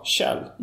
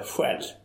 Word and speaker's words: Kjell: Och på engelska så heter, uttalar man snäckskal Kjell, inte Själv.